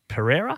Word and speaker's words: Pereira: I see you got the Pies had the UFC middleweight, Alex Pereira.